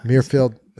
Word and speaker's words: Muirfield: Is it Muirfield? – Muirfield.